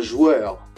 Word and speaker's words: joueur: You say un joueur. – Un joueur. – Un joueur. – Un joueur. You spell it J-O-U-E-U-R.